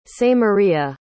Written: samaria

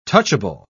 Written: touchable

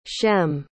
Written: shem

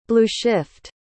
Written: blueshift

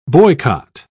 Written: boycott

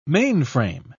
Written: mainframe

